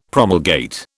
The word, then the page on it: promulgate: British English